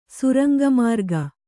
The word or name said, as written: ಸುರಂಗಮಾರ್ಗ